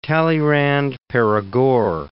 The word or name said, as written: Talleyrand-Perigord